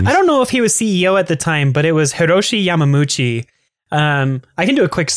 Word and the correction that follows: Yamauchi: I mispronounced it. It's like Hiroshi Yamauchi, I think.